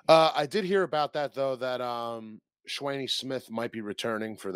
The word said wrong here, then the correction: Shawnee: How do you say Shawnee? – Her name? – Shawnee. – Is it Shawnee? – It's Shawnee.